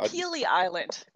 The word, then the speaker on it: Pelee Island: Yep. It's called Pelee Island. P-E-L-E-E.